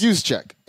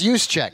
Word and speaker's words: Juszczyk: You mentioned Kyle Juszczyk. – I know. I can't pronounce it. It's Juszczyk. – It's Juszczyk.